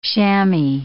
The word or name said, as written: chammy